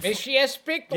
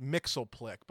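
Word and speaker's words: Mxyzptlk: My Mishy S. Bickler. – Yep, exactly. Which, for whatever reason, when I was a kid, I always pronounced as just Mixleplick. But anyway, I'll defer to you.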